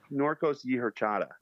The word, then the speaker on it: Norcos y Horchata: But it's Norcos y Horchata, is that correct? – Yeah, pretty close. Yeah, pretty close. – Norcos, it's like Norcos y Horchata.